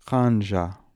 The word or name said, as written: Hanja